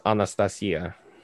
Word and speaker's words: Anastasia: So Russian is Anastasia. So Anastasia. You're all pronouncing it wrong.